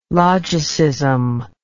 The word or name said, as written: logicism